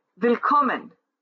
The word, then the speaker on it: Willkommen: Willkommen, Rumänien have 3 syllables, and the middle syllable is accentuated.